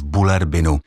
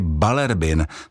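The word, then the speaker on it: Bullerbyn: Někdy slyším taky Balerbin, což je výslovnost, která se zřejmě snaží respektovat, že ve skandinávských jazycích, stejně jako třeba v angličtině, se slova často vyslovují jinak, než se píšou. Ale v případě Bulerbinu je výslovnost opravdu taková, jak vypadá psaná podoba toho jména.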